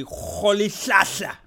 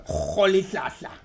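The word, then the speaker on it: Rolihlahla: Nelson Mandela, his second name would be Rholihlahla. – There you go. – Rholihlahla.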